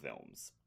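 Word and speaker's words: Vilmes: It's spelled V-I-L-M-E-S. I think it's Vilms.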